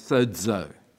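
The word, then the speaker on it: sōzō: It's a word in Greek that we would pronounce as sozo.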